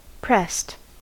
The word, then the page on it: pressed: American English